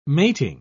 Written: mating

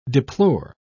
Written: deplore

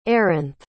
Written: eranthe

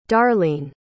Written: darleane

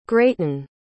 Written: grayton

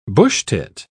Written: bushtit

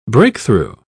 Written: breakthrough

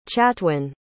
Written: chatwyn

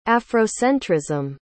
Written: afrocentrism

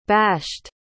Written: bashed

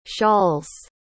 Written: shawls

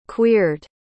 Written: queered

